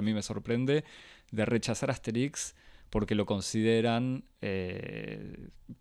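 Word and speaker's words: Astérix: La primera es la que me parece la más urgente, incluso 60 años después de la aparición de, de Asterix Asterix se escribe con tilde en la E y se dice Asterix como si fuese una palabra aguda, o sea, con acento en la I.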